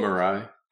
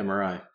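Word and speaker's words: Mirai: And Maria is actually really good too. – You mean Marai? – Is that how you say it? Marai? – Yeah, Marai.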